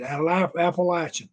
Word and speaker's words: Appalachian: They put me in a little office and taught me how to pronounce Appalachian. This is Appalachian, Lash, Appalachian.